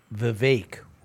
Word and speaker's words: Vivek: So, you know, Vivek rhymes with cake.